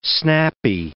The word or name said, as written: snappy